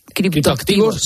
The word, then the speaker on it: criptoactivos: Hay adicción, me ha hecho mucha gracia cuando al principio has descrito y has dicho los criptoadictivos, ¿no? Me ha hecho mucha gracia. – Sí, porque lo he dicho mal. Era criptoactivos.